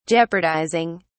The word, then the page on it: jeopardizing: British English